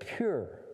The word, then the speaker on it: pur: And in the language of that day and in Persia, the lot, the word for lot was the word pur. P-U-R is the way we'd spell it in English.